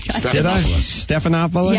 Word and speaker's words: Stephanopoulos: Now, speaking of boyfriends, you're married to George Stephanopoulos? – If you said his name right. – Stephanopoulos? – Yes. I am.